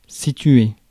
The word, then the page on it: locate: American English